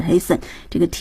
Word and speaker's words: hasten: More haste less speed 那个 hasten 它的动词是 hasten、这个 T 也是不发音的、和我们的这个 fasten 是一样的、OK, let's go over the text then.